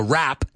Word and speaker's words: wrap: Now, rap, W-R-A-P, is pronounced exactly the same as the music, rap. Right? La única diferencia es rap. En este caso tiene W, muda, right?